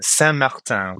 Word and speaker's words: Saint-Martin: So there was this proposal that came up to buy a resort in the Caribbean, and Saint-Martin was the place.